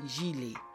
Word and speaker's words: gilet: And just like the C, the G can make two sounds. A hard G sound, like G, like in gâteau, and a soft G sound, like je, like in gilet.